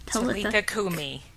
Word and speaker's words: Talitha Kumi: How do you say that? – Talitha Kumi.